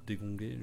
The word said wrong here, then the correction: dégondé: je ne sais même pas comment on prononce. C'est même dégondé. Pas s'ils disent. – Dégondé, si, ok.